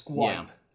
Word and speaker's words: Skype: This is the first time we're using something called Skippy. – Yeah, Skype. – Skippy. – I think you pronounce the Y in full, so it's Skype.